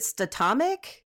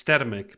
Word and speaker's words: Statamic: I thought it was like, is it statomic? – It's statamic, but Jay mispronounces it all the time.